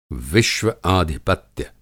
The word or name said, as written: विश्व-आधिपत्य